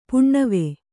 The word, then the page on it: ಪುಣ್ಣಿವೆ: puṇṇive